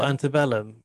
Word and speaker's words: Antebellum: Antebellum. – Oh, Antebellum.